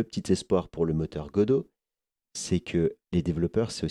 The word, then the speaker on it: Godot: Le deuxième moteur en tête arrive Godot, avec un T à la fin, mais on prononce Godot.